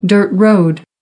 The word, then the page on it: dirt road: American English